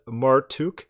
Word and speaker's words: Marduk: The name Marduk was probably pronounced Martuk.